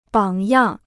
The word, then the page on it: 榜样: bǎng yàng